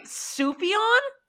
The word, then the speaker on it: Sufjan: Is that how you say it? Surfjan? I've been saying it this whole time like that. – Sufjan. Surf Jam Steve. – Sorry, Sufjan? – Sufjan.